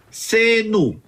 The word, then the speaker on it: Xenu: Ojo, esta gente sí cree en extraterrestres, que se llamaba Xenu. No como Xavier, que es Xavi. Este es Xenu, Xenu con X, como se escribe Xavi.